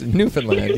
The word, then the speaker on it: Newfoundland: And it's pronounced Newfoundland. Yeah.